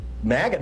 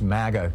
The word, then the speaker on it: MAGA: I have heard, by the way, apropos of the pronunciation of MAGA, that some Republicans are saying it shouldn't be pronounced MAGA because that sounds too much like maggot, and therefore they want it to be pronounced as MAGA, which sounds kind of like mama or something.